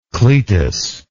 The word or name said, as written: cletus